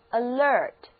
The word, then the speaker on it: alert: 在 ERT 表示精力这个词根的前面加上 al，这个表示加强音的前缀，读成 alert, alert。注意重音在后面一个音节，alert。